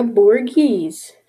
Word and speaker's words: Borghese: Next, we're going to move slightly north, not slightly, a lot north, to the Galleria Borghese. I think I'm saying it right. Yeah, I am.